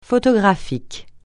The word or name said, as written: photographique